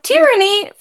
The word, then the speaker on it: tyranny: But with tyranny, for some weird reason, we emphasize the first syllable.